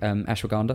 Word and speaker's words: ashwagandha: So that's why supplementation with things like ashwagandha, is that how you pronounce it? – Yeah.